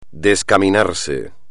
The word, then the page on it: descaminarse: espagnol